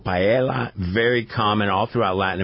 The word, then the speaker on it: paella: There's an L, paella.